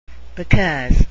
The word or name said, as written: because